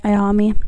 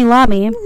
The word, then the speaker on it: Iommi: We have the guitarist, Tony Iommi? – Iommi. – Iommi? Possibly. – Fun fact, totally thought that was an L my entire life and never heard it pronounced. So I was like, Tony Lamy. Tony Lamy, the guitarist? Tony Lamy? Embarrassing.